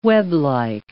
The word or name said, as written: weblike